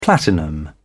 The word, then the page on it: platinum: British English